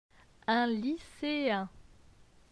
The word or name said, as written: Un lyceen